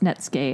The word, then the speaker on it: Netsuke: So that was just it's actually spelled, it looks like Netsuke. – Yeah. – But it's pronounced Netsuke.